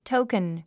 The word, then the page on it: token: tóukən; トゥヶン